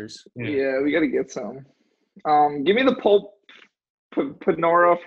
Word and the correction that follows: potpourri: for 400. – Pulp Panora. – Oh, I forget it. – Potpourri. – Potpourri. – That's for 400. Said it wrong.